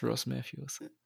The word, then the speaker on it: Ross Matthews: Ja, Style Superstar Carson Cressley hat sich nicht so durchgesetzt wie The Hilarious Ross Matthews.